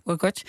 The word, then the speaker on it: Hurkacz: Maar als ik dan het filmpje zie van, en dan moet ik het goed uitspreken, van de tennisser Hurkacz, zeg ik het zo goed? – Ja, Hurkacz.